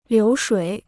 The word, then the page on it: 流水: liú shuǐ